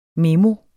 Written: memoet

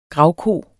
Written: gravko

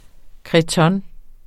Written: cretonne

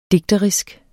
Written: DIGTERISKE

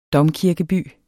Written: domkirkeby

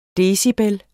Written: decibel

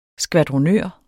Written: skvadronør

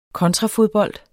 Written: kontrafodbold

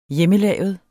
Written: hjemmelavet

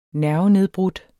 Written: nervenedbrudt

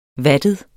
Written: vattet

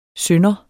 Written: synder

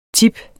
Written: tips

